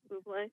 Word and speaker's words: Bublé: I also agree with Herbie about, I'm going to say the guy's name wrong, Michael Bublé. – Bublé.